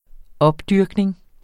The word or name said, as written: opdyrkning